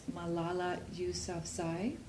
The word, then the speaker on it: Malala Yousafzai: There's a young Afghani woman, and I'm going to apologize in advance if I mispronounce names, but I believe that her name is pronounced Malala Yousafzai.